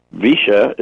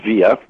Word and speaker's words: Villa: Visha is the way they pronounce villa down there, V-I-L-L-A. Anywhere else is pronounced Via. Down there is Visha.